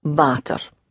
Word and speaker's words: water: Water. So the W is pronounced like a V and is a real long open ah sound with a slight touch of the R at the end. Water.